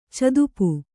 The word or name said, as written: ಚದುಪು